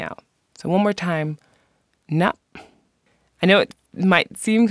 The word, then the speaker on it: nafn: The Icelandic word for today is appropriately name, which is pronounced nap, nap. And if you look on my website at this word, that in English, the letters would be N-A-F-N, the F sounds like a P, and then they have this kind of breathing out through the nose sound that's happening at the end.